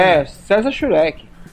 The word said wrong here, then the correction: Schwenk: César, César Schwenk.